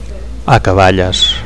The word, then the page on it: acaballes: Catalan